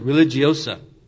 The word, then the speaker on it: religiosa: Sort of an anorexia religiosa. Mispronounced it. Religiosa. Anorexia religiosa.